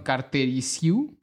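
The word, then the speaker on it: Cărtărescu: Y el, el tercer caballo importante es Mircea Casterizau, ¿lo ubican? No. No, perdón, Cartericiu.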